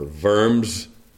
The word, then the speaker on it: Worms: Many of you are familiar, I'm sure, with the story of Martin Luther's appearance before the Diet at Worms, or Worms, as it's sometimes pronounced.